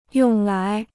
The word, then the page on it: 用来: yòng lái